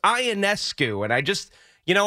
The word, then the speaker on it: Ionescu: It's Ionescu. That's how you pronounce the last name of Sabrina Ionescu.